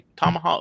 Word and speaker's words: Tamaha: She goes back home to uh, uh, t- t- hang on, I'm going to try and say it right. Tamaha, Tama is that right? Tamaha, Oklahoma. Mm-hmm.